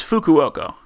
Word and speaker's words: Fukuoka: I believe it's Fukuoka.